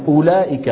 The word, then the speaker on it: أولئك: أولئك، الواو في أولئك، شو بنضع على الواو؟ صفر مدور. أن هذه الواو لا تنطق أبدا، ما حدا بيقول أولئك على هدى من ربهم وأولئك، ما حدا يفعل ذلك.